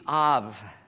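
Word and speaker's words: Av (אב): In Hebrew, the word for father is A-B, Av, pronounced with a V sound, but it would be like a B in Hebrew.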